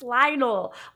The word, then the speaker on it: Lionel: Like, it's Lionel. Why are you adding in all these extra syllables?